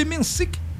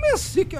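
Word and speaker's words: Menšík: O nosso querido tcheco aqui, o Jakub Mensik, como é que fala o nome dele? – Eu falei o Mensik aí, mas Mensik, tá bom.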